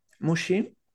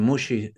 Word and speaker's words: Muxi: Entonces yo me llamo Dani Mushi. También tienen la incomodidad que Mushi suena como si fuese sushi, pero se escribe con X, entonces Dani Muxi.